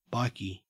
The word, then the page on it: bikie: Australian English